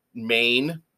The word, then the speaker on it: Mein: Another standout was Mine, M-E-I-N, or Maine, like Chow Maine, how you pronounce it, Mine, Maine.